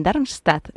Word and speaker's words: Darmstadt: en Darmstadt, no sé si lo he dicho bien, al sur de Frankfurt.